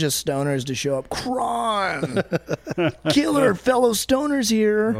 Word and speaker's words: Cron: And Cron is Cron. – But the O has the little line over it. – It's called long O.